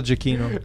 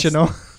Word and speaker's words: Giacchino: I'm saying it the Italian way, the way it was meant to be pronounced. – Giacchino.